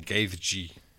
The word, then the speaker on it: Gaethje: The headline fight was Dustin Poirier versus Justin Gaethje. That's how you pronounce his name.